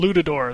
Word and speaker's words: Lutador: They said it Latador? In the game. Latador, yeah, because it sounded like Matador or something. Luchador or something. Lutador, that's what they're called.